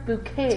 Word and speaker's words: Bucket: I love how she's like, it's pronounced bouquet. – It's pronounced bouquet. – And spelled bucket.